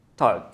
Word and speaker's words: Montag: Montag, yeah, that's what I think.